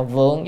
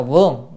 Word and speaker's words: vượng: Chữ này đọc là vượng chứ không đọc là vương. Vương á là vua, nhưng mà đọc vượng là động từ, là cai trị, là quản lý á.